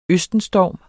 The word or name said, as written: østenstorm